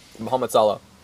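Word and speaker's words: Mohamed Salah: Muhammad Salah.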